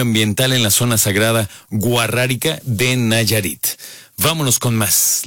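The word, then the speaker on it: Wixárika: Sí, es la comunidad guarrárica, pero lo difícil de esto es que se escribe con W y X, que es a lo mejor fonéticamente sería como huaxárica, pero ellos se hacen llamar guarráricos.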